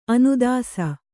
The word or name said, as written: ಅನುದಾಸ